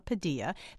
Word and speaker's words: Padilla: And by the way, no need to write in and tell us we're mispronouncing Mr. Padilla's name. That's right, not Padilla. That is the way he and his family prefer it to be pronounced.